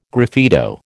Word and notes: graffito: American English

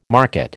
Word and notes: market: American English

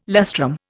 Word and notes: lustrum: Indian

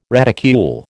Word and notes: reticule: American English

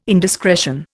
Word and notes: indiscretion: Indian

